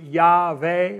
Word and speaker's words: Yahweh: There are no vowels in the Hebrew language. We add the vowels later, so we put an A and an E in there, so we pronounce it Yahweh, YHWEH in the Hebrew.